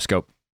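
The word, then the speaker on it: Schoop: And then the Brewers, with their trade deadline moves, adding Mike Moustakas and Jonathan, how do you say his last name? Scope? – Yep, Scope.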